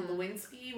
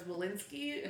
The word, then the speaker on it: Lewinsky: is mispronounced as Walensky often, and Lewinsky was mispronounced as Walensky occasionally, and so somebody made a stupid joke, and they said Walensky instead of Lewinsky, and suddenly I was called Monica for a while, which was fine by me, because I found Bill Clinton incredibly sexy.